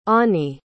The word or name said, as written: onnie